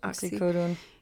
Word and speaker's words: oxycodone: Oxycodone.